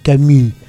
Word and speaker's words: Camus: Ah, eso me lo enseñó alguien que sabía francés, me dijo, no se dice Camus, se dice Camus.